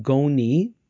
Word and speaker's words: Goni: October the 31st, Typhoon Goni,